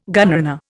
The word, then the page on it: gunrunner: Indian